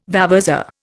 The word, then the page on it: vavasour: Indian